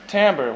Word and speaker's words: timbre: It looks like timber. And if you were French, you would probably pronounce it timbre or something like that. At least we want to imagine that we would. So, timbre.